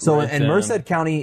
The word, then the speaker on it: Merced: Merced.